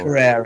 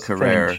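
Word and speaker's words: Carrère: Yeah, like I was reading about how you wanted to put a record out with Career, if that's how you pronounce that label. – Yeah, Career, it was Career. French, yeah.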